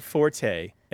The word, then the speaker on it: forte: Just to be clear, forte is the now accepted pronunciation. The people mm-hmm. who insist that it isn't, all of their arguments that I've ever heard are incorrect.